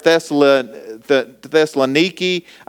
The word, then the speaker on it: Thessalonica: Now, I pronounce it Thessalonica, and that's how I've heard it pronounced. And studying this week, I heard it pronounced two different ways. And I've heard Thessaloniki. I've heard Thessalonica. The way I've always heard it, and the way that I've seen it, you know, when I went to check, it was Thessalonica. And so Thessalonica, Thessalonica.